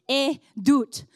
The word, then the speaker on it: edut: The word testimony in the Hebrew is pronounced edut, which comes from the root word ut, which literally translates to do it again.